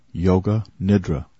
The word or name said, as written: yoga nidra